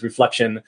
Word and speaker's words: RefleXion: Tell us a little bit about your experience leading up to starting RefleXion, or some folks call it RefleXion, but I think the proper way to say it is RefleXion.